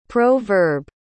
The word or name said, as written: pro-verb